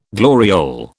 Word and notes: gloriole: British English

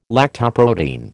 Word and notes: lactoprotein: American English

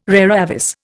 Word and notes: rara avis: Indian

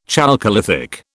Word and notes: chalcolithic: British English

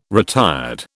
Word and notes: retired: British English

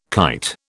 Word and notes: kite: British English

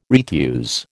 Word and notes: retuse: American English

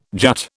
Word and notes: jut: British English

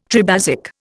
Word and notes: tribasic: Indian

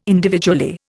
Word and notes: individually: Indian

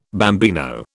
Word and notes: bambino: British English

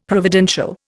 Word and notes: providential: Indian

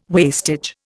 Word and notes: wastage: Indian

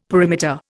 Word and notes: perimeter: Indian

